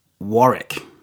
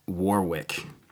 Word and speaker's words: Warwick: They very patiently advised me that it's pronounced Warwick, not Warwick.